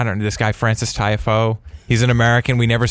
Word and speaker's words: Tiafoe: Tiafoe. Tiafoe. – Okay. – It's pretty much how it looks.